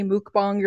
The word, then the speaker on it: mukbang: I think it's like maybe mukbang or something like that. Or mukbang, mukbang, Mukbang is the way that you're supposed to pronounce it, but everyone just calls it a mukbang because it's spelled M-U-K-B-A-N-G.